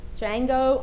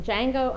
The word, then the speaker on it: Django: Yes, I saw Django Unchained. Anybody else see it? Anybody admit to it? The D is silent.